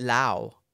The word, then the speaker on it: Laos: I have been to Laos, but I call it Lao. I don't know which is the proper. – Yeah. – I hear both pronunciations, Lao and Laos.